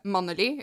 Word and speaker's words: Mannelig: It says here that, and I'm going to pronounce this the Norwegian way, so it's going to sound very stupid, probably mixed with English, but the head honcho of the town is called Manneli, is how you would say that in Norwegian, which is very close to Manly, if you translate it.